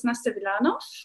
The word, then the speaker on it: Nastja Vilanov: Ja, äh, mein Name, wie du schon richtig gesagt hast, ist Nastja Vilanov.